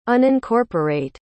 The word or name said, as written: unincorporate